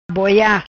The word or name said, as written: Boia